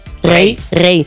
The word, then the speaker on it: Raye: Y vamos con una canción de Raye, ¿cómo se dice? Raye. Rey, que es como la que decía una mujer cuando quería abrirse una cuenta del banco antes de 1975.